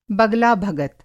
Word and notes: बगलाभगत: बगला-भगत

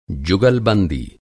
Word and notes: जुगलबंदी: جگل بندی

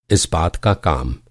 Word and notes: इस्पात: اسپات

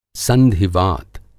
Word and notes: संधिवात: Hindi

